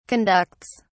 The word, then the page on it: conducts: British English